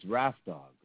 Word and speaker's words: Rap Dog: It's Rap Dog, right? – No, no, no, no.